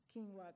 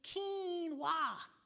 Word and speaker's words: quinoa: Quinoa comes to the hood. First of all, nigga, it's quinoa, not quinoa.